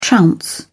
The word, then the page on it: trounce: British English